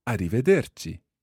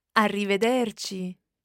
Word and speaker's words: arrivederci: However, there is a proper word for goodbye, and it is arrivederci. Let's hear the proper pronunciation of this. – Arrivederci.